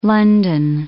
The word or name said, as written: london